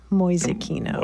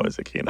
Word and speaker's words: Mäusekino: Mäusekino. – M-A umlaut U-S-E-K-I-N-O. Mäusekino. – Mäusekino.